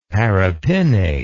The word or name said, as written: paripinnate